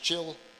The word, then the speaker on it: Chilion: That's how it's pronounced, Chilion.